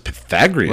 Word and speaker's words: Pythagoras: That is Pythagoras.